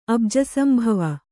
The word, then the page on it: ಅಬುಜಸಂಭವ: kannada